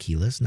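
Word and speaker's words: Kelis: Can you name the 2003 Keyless, I believe it's pronounced Keyless, not Kellis, song about bringing boys to the yard? – I have no idea about that one.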